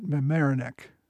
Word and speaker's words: Mamaroneck: Their next encounter with the British was, if I can pronounce it right, at Mamaroneck. – Yes, you got it.